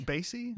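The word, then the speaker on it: Basye: Basie? Rhymes with lazy? – Lazy?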